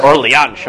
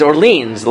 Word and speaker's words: Orleans: Like, they're like, the Re of Orleanish, or Orléans. I'm like, no, like, it's Orleans. Like, pronounce it like an American, pronounce it like a Frenchman, but don't pronounce it like, I don't know what that is, right?